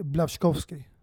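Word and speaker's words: Błaszczykowski: Blaskikovski. – Nej, tre gånger i rad snabbt. Det, uttalas... – Blajikovski. Nej, det uttalas faktiskt Blavskovski.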